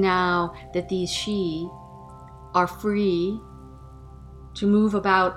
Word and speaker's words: Sidhe: Some of the Xi, this word, if you're not familiar with it, is spelled S-I-D-H-E. Some of the Xi